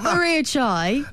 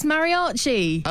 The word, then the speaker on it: Mariachi: It's not Maria Chai. – Okay. – It's Mariachi.